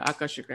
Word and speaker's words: Akashic: Yeah, Akashic records.